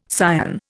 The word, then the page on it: cyan: Indian